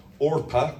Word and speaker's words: Orpah: Orpah, not Oprah, Orpah.